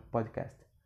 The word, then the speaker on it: podcast: Então, como eu falaria se eu tivesse numa entrevista de, de emprego, eu queria, se eu quisesse mencionar o meu podcast? Eu falaria podcast. P-O-D-C-A-S-T. Pod. Lembre que esse D você meio que vai engolir ele, você vai pronunciar só metade.